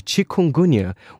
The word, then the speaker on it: chikungunya: chikungunya, which is the same one that transmits dengue, which actually is quite a common disease in Brazil.